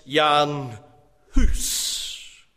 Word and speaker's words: Jan Hus: The Czech spelling of John Huss's name is Jan Hass, actually pronounced Jan Hus. Rather intriguingly, Hus rhymes with the English translation of his last name, Goose.